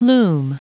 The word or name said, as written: loom